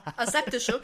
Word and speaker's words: aseptische: Es wirkt einfach alles wie eine sehr aseptste. Heute kann Finn Worte nicht aussprechen. Es wirkt alles sehr wie eine sehr aseptiste. – Aseptische. – Aseptisch heißt das schwierige Wort.